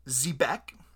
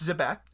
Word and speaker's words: Xebec: It is an anime studio called Zebek, spelled X-E-B-E-C, pronounced Zebek, and I want to give you some of their highlights.